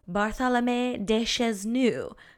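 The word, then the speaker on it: Barthélemy de Chasseneuz: Oh my God, my French is so bad. Hold on, we're gonna figure out how to pronounce this better. Okay, we're back with pronunciation. This lawyer's name is Bartholomé de Chazenuise.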